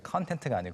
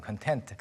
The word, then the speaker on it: content: 발음 이제 content가 아니고 content.